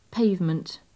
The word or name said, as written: pavement